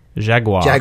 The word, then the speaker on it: Jaguar: Have you heard it, how they say it? – It's like Jaguar.